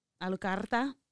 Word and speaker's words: Alucarda: It's like Dracula with the A at the beginning. Alucarda is what it's how it's pronounced.